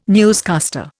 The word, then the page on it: newscaster: Indian